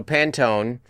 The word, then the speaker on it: Pantone: So, Pantone, am I saying it right?